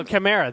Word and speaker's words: Chimera: No, Chimera. That's it. Can you tell? – I can't blame you. The first time I heard that word pronounced that way, you know, correctly, was Mission Impossible 2.